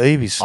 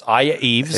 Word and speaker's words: Aya Yves: Aya Eves. No, not Eves. Oh, you made the mistake. Eves. It was you. It was you. – It was mispronounced on the show the other day. That's Aya Eves. A-Y-A-Y-V-E-S.